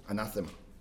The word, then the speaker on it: Anathema: Ich denke, das ist die richtige Betonung. Anathema.